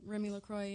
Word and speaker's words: Remy LaCroix: Oh, man, like, I think recently, I don't know if the scene even came out yet, but I saw a tweet by, I don't even know if I'm saying her name right, but her name is like Remy LaCroix. – Yeah, Remy LaCroix, yeah.